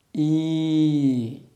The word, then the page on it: ī: sanskrit